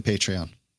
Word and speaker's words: Patreon: Is that the way you pronounce it? – It is not. It's totally Patreon.